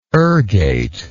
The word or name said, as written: ergate